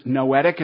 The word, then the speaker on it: noetic: That is the phonetic sounding. It is noetic.